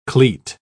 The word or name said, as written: cleat